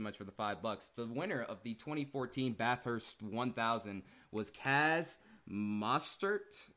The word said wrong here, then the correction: Chaz Mostert: I think that's how you say it. – Chaz Mostert.